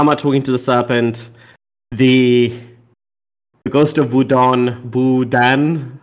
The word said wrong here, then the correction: Boudin: How did how do you say this, Emma? – Boudin. – Boudin. Uh, thank you so much.